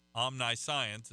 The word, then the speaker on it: omniscience: And so when you look at omniscience, which is O-M-N-I-S-C-I-E-N-C-E, omniscience is the way it would look like it would be pronounced, but it's omniscience.